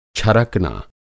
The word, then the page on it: छरकना: Hindi